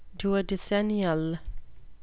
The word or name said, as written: duodecennial